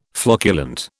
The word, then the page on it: flocculent: British English